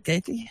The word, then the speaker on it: Gaethje: Is that how you say it, Gaethje? – Yeah, that's it.